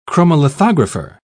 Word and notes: chromolithographer: British English

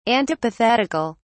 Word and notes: antipathetical: British English

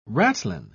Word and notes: ratlin: British English

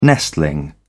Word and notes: nestling: British English

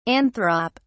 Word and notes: anthrop: British English

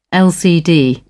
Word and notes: lcd: British English

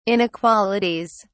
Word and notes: inequalities: British English

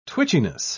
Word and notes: twitchiness: British English

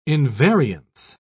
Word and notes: invariance: British English

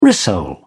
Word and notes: rissole: British English

